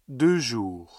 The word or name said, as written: deux jours